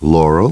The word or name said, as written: Laurel